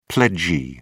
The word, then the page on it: pledgee: British English